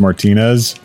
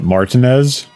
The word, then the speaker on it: Martinez: Also, where I lived in Augusta, there was a place called Martinez, and it was Martinez. – French. It's Martinez. – No, it's not French. – It is. – What, Martinez? – Martinez.